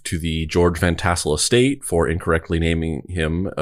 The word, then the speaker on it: George Van Tassel: Now I'm looking at his name and I'm like, is his name George Van Trussel or is it George Van Tussel? Hold on. I think I said it wrong. – This is George Van Tassel.